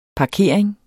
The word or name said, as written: parkering